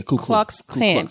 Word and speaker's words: Ku Klux Klan: The police started as the coup. Am I saying it right? Clue? – Yeah, Ku Klux Klan.